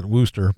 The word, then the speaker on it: Worcester: If you were going to spell Worcester, how would you spell it? – Well, I'd say it Wooster probably, but that's because I'm Worcester. Worcester. Weird. That's how they say it on the East Coast. Worcester. – Yeah. – Worcester. – W-O-O-S-T-E-R. – How about this? W-O-R-C-E-S-T-E-R.